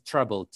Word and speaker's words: Treblle: This is pronounced as Treblle, T-R-E-B-L-L-E.